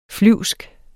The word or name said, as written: flyvsk